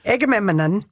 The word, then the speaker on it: Agamemnon: Amy Henderson, a historian at the National Portrait Gallery, said Agamemnon goes away to fight. Did I say it right, Donnie? – You threw in an extra syllable. That's okay. – Agamemnon.